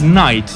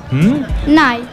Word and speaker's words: knight: The knight, which is un caballero, it's all in the story that I'm going to read out, it's pronounced as knight. – Knight. – Yes, it's got a K at the beginning, but it's not pronounced. It's just knight.